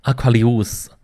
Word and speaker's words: Aquarius: 那么这个 Aquarius，或者咱们按照拉丁文的这个读音规则，应该是 Aquarius 啊，好吧，这个大舌音我发不出来啊。